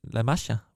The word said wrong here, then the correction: La Masia: hvordan udtaler man det? La, La, La Masia for helvede. La Masia.